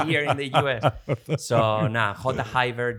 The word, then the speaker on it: J'hayber: Is there multiple ways you can pronounce it, or is there just one way and that's the way I will say it's one way, but it's true that Spanish people tend to pronounce the J very jota, very hard, but jota Haber.